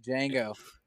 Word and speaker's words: Django: And then in my number five spot, I'm going to have to put Django. – Django. – You mean classic. Django. – The D is silent.